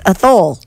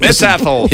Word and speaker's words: Athol: A-T-H-O-L. – That's Athole. – It's Athole.